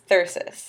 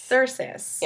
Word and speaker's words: thyrsus: Dionysus is Thyrsus. Thyrsus. Yes. – Okay. So it's T H Y R S U S.